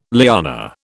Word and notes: liana: British English

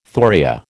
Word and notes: thoria: American English

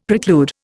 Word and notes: preclude: Indian

